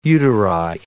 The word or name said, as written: uteri